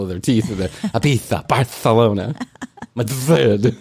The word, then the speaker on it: Ibiza: It's Ibitha, not Ibiza. – It is. – It's spelled Ibiza, but it's Ibitha.